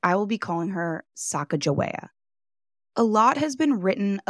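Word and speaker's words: Sacagawea: Many believe that the Hidatsa pronunciation has a hard G sound, and instead would be pronounced Sacajawea, translating to bird woman, and this is likely how it was pronounced by the Corps of Discovery.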